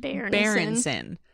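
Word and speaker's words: Byrnison: Speaking of mismanaged talent, we get to meet Yorick Berenson. So I can't forget to pronounce the bear in that name. – Berenson.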